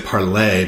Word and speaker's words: Parler: I want to say Parlay because it's like the French.